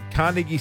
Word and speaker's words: Carnegie: I think it's your Queensland accent that's doing you in. – We're going to be outside Spotlight. I'm saying that right. Level 1, Carnegie Central. – Yeah, you got it.